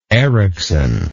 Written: Ericson

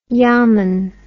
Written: yamun

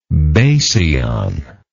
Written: basion

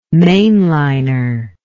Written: mainliner

